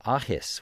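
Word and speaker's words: Athas: Tomorrow on the show, we'll have a live performance by a Celtic trio called Ahis, which is spelled A-T-H-A-S. I do not understand Celtic pronunciation at all, but that is how you apparently say that name, and they're going to be performing on Saturday at the Channing Murray Foundation in Urbana.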